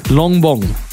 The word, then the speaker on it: Longbong: You also have another moniker named Long Bong. – Yeah, because that's my name. – Is that how you pronounce it? – Yeah, yeah, Longbong.